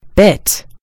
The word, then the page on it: bit: American English